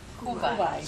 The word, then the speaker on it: Kuwait: How do you pronounce it? It's over there. And the it's a little nation on the edge of Iraq, Iran. – Kuwait. – Say it. – Kuwait. – Yeah.